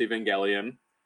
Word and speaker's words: Evangelion: I don't know anything about Neon Genesis Evangelion, except that you're supposed to pronounce it with that kind of G instead of the J-G.